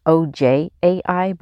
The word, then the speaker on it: Ojai: And it pronounced OJAI, but it's Ojai, California.